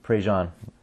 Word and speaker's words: Prejean: And her name is Carrie Prejean, I think is probably how you pronounce it. What's that? – Prejean.